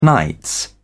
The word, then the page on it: nights: British English